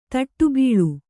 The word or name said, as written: ತಟ್ಟುಬೀಳು